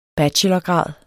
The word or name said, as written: bachelorgrad